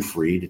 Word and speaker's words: Fried: Jim Freed. It's spelled F-R-I-E-D, like Kentucky Fried Chicken.